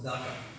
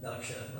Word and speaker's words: daksha: And Prabhupada calls it daka, because I think it's part of the Bengali accent that when you have a K and an S, it's pronounced like K, like Kshatriyas and daka instead of Kshatriya, and daksha, like we say.